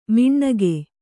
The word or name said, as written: ಮಿಣ್ಣಗೆ